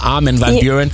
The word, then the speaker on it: Armin Van Buuren: Ich habe irgendwann mal Armin gefragt und er so, sag's im Englischen, dann ist es viel, viel einfacher. Armin Van Buuren. Deswegen sagen wir Armand Van Helden.